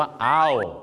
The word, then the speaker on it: owl: กฮูกต้องออกเสียงว่าอ้าวไม่ใช่โอนะครับตอนนี้อุทานอ้าวเหรอออกเสียงว่าโอมาตลอดชีวิต wise as an owl